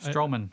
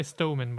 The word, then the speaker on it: Strowman: I was going to say Stowman, but I knew that was wrong.